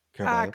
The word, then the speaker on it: Caraballo: Caraballo.